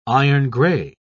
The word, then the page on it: iron-gray: British English